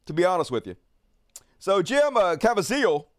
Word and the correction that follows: Caviezel: somebody said it rhymes with weasel, Caviezel, is that how you pronounce it? Uh, Jim Caviezel Made a movie like eight years ago.